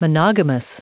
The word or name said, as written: monogamous